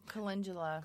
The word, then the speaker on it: calendula: Now, you can also make a poultice of calendula. I had never pronounced that right. – I know. Calendula.